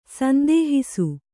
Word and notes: ಸಂದೇಹಿಸು: sandēhisu

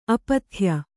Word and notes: ಅಪಥ್ಯ: apathya

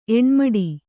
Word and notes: ಎಣ್ಮಡಿ: kannada

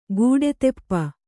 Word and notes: ಕಡುತಪ: kaḍutapa

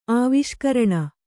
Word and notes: ಆವಿಷ್ಕರಣ: āviṣkaraṇa

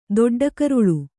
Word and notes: ದೊಡ್ಡಕರುಳು: doḍḍa karuḷu; Kannada

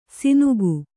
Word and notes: ಸಿನುಗು: sinugu

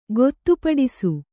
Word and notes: ಗೊತ್ತುಪಡಿಸು: gottupaḍisu

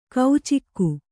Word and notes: ಕೌಚಿಕ್ಕು: kaucikku

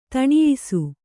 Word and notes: ಧನ್ಯಾಸಿ: dhanyāsi

